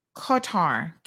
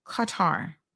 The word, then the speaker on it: Qatar: The accepted pronunciation would sound like Qatar, Q-U-H-T-A-R, Qatar.